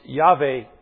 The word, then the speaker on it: Yahweh: And it is best pronounced Yahweh, also known as the Tetragrammaton, a new word we get to learn this year. It simply means those four letters, YHWH. And the vowels are then supplied to come up with that pronunciation, Yahweh.